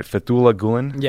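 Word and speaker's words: Fethullah Gülen: So, Ben, who is, am I saying his name right, Fethullah Gulen? – Yes. Yeah.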